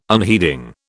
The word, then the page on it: unheeding: British English